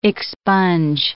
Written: expunge

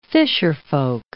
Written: fisherfolk

